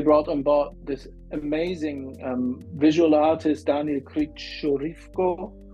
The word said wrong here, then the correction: Krivoruchko: I need to learn how to pronounce his name properly. Krivoruchko, Daniel Krivoruchko, who is a very experienced digital artist,